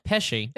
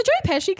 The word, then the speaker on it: Pesci: Pesci.